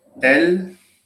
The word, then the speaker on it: elles: But there is no difference in the pronunciation. Il va, ils vont. The same for the pronoun she, elle, va or elles vont.